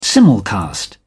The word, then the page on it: simulcast: British English